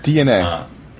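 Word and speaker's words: DeNA: So, Dina, or Dana, or whatever. – DNA. Is the correct pronunciation.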